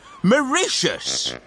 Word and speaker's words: Mauritius: It's pronounced Mauritius, said Beaver.